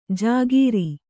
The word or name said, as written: ಜಾಗೀರಿ